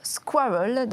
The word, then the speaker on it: SQRL: Et cela se prononce squirrel dans mon bon anglais, qui veut dire écureuil.